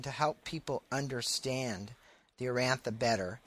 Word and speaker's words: Urantia: And just a little correction on pronunciation. It's Urantia.